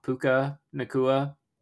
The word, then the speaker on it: Puka Nacua: And the Rams, you know, Puka Nacua.